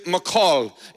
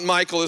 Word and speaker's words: Michal: And the name here is Michal. It's like you would say a macaw. It's not Michael, as most would pronounce it. It's Michal. But Michal, David's wife, warned him, if you don't escape tonight, you will be dead by the morning.